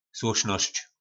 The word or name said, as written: slosnosc